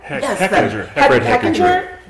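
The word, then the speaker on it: Fred Hechinger: And I guess we have to mention Fred Heidenlinker. – Fred Heckinger. – Completely butchered that.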